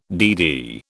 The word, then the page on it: DD: British English